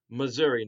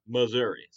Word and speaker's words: Mazuri: It's Missouri, not Missouri. So it's not like the southern state. It's spelled M-A-Z-U-R-I.